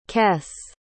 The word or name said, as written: kess